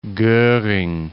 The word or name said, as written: Goring